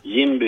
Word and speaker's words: Jimby: God morgon, du är samordnare för nätverket Jimby, uttalas det va? – Jimby Göteborg, ja precis.